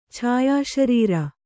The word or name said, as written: ಛಾಯಾಶರೀರ